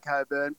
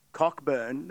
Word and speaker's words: Cockburn: I went to a place called Coburn, and I don't know if you guys know about Coburn, but apparently the people who live there call it Coburn. Everyone else knows it's Cockburn. That's how it's spelt, which is ironically what you get if you meet a girl in Coburn.